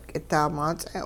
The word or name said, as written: k'idaamaats'e'